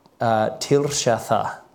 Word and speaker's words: Tirshatha: Verse 5 says, the Tirshatha, that's how you say that. I do actually know how to pronounce that one, I think.